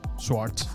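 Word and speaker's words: Schwartz: Swartz, sí.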